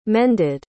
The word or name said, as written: mended